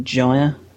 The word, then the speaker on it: Gyre: I also, I believe it's pronounced Widening Gyre, not Gear. – Is it Gyre? Yeah. – I think so, yeah.